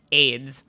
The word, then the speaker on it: Ayds: By far the most bizarre diet trend that she ever partook in was the AIDS candy diet, spelled A-Y-D-S, pronounced AIDS.